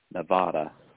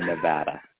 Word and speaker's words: Nevada: So a lot of people will say Nevada. That's a sin. You got to say Nevada.